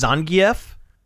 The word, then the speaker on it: Zangief: Yeah, did you know that Zangief is actually pronounced Zangief? – Really? – Yeah. – I didn't know that. – Apparently, it is actually pronounced Zangief, and they pronounce it that way in Street Fighter V.